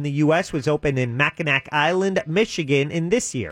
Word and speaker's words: Mackinac: Oh, it's Mackinac. Well, if they want Mackinac Islands pronounced Mackinac, change that C to a W.